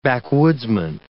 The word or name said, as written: backwoodsman